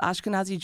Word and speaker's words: Ashkenazi: And then I also read something about being of Eastern European descent, the Ashkenazi Jews. – Yes. – I think I said that right. – Yes, Ashkenazi Jewish individuals, which about 90% of Jewish individuals in America have that ancestry.